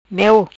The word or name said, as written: Meo